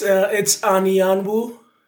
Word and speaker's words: Anyanwu: it's Anyanwu.